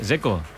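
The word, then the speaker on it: Džeko: A mí el que me parece difícil es el de Checo, Dzeko, porque DZ, ¿cómo se pronuncia? – Dzeko. Bruno dice que es Dzeko. – Como tirando a J, Dzeko o algo así.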